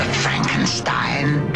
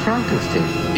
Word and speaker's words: Frankenstein: Dr. Frankenstein. – It's pronounced Frankenstein.